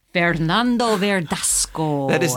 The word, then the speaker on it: Fernando Verdasco: Fernando Verdasco. Am I saying it correctly? – You're saying it correctly.